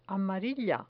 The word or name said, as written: amarilla